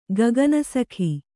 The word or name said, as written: ಕಾಕನಾಸಿಕೆ